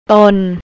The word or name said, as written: ton